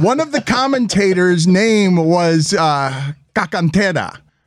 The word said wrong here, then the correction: Carcaterra: I don't, that can't be the way you pronounce it. – I think it's Paul Cacantera. It sounded like Cagalera. – Carcaterra.